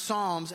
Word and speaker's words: Psalms: Psalm starts with a P, but it's pronounced Psalms, and there's 150-something songs there.